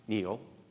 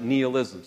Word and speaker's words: nihilism: He espoused nihilism. Not Neil, but nihilism. Some people pronounce it nihilism.